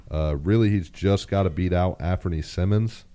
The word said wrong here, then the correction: Simons: or Simons, I believe is the proper pronunciation there.